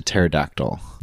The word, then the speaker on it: pterodactyl: And then all of a sudden you devolve into pterodactyl. – Yeah, that's right. Well, at the end of the day, it always devolves into... – It always goes to the silent P.